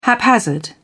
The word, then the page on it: haphazard: British English